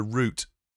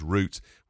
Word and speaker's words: route: No, if you went on a route to go somewhere, it would be R-O-U-T-E, the same as you'd spell it, but we'd pronounce root, whereas you'd say route.